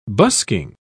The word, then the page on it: busking: British English